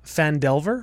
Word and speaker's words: Phandelver: We are probably going to do Lost Mine of Phandelver. Am I saying that right? – Yep.